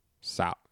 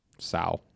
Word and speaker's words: Cao: C-A-O. How would you say that? – Sao. Yeah. – Asian, though. – Sao. – Sao? Yi Cao? Y-I-C-A-O.